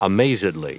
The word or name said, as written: amazedly